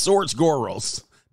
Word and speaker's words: George Soros: Or George Soros. I keep saying it wrong. It's George Soros.